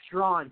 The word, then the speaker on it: Straughan: Karen, is it Strahan? Is that how I pronounce it? – Strawn.